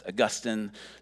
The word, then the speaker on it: Augustine: Augustine, one of the early church fathers, it looks like it's pronounced Augustine. It's pronounced Augustine, just to give you all a theological lesson here.